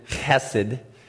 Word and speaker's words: chesed: C-H-E-S-E-D. Apparently, you're supposed to pronounce it hesed.